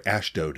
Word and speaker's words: Ashdod: Ashdod is actually how it's pronounced.